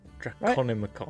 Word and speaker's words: Draconomicon: Oh, I'm not going to say this right, am I? The Draconomicon. – Draconomicon. Right? – There you go.